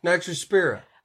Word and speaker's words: Nitrospira: How did you say it? – Nitrospira.